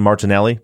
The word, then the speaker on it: Martinelli: And for all of you listeners, this is Maggie Freleng and Julieta Martinelli.